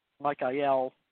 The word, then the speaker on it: Michael: So people mostly are only familiar with Michael, or I pronounce the L, Michael, or Gabriel, because L in Hebrew means of God.